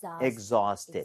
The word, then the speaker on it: exhausted: AU나 AW 스펠링은 대부분 어에 가깝게 발음이 돼요. – 네. – 아우가 아니라. – 예. – So exhausted. – Exhausted. – Exhausted. 이렇게 발음을 하는 게 맞습니다.